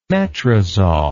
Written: metrazol

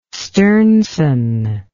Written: sternson

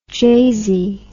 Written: jazy

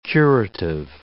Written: curative

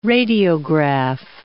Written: radiograph

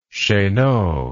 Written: cheneau

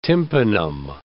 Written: tympanum

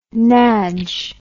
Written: nadge

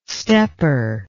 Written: stepper

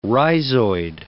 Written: rhizoid

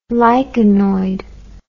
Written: lichenoid

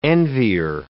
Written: envier

